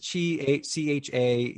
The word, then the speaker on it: Chapek: Please pronounce his name for Bob Chapek. – Because it's Chapek.